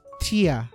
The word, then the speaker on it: Tchia: Ang spelling niya is T-C-H-I-A. – A. Uh, Pagkakarinig ko dun sa trailer, Tia. Tia. So, Um, anong masasabi mo dito Kuya Bulls?